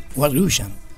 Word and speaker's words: White Russian: Tom Why Russians. – White Russians.